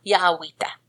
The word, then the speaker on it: Jowita: We're going to start with Hamza and Jowita, which is spelled J-A-J-O-W-I-T-A, Jowita. – Jowita. – No, it's Jowita. Not Jowita. Yawita.